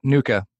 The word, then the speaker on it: NUCCA: NUCA, is that how you say it? – Yep, NUCA.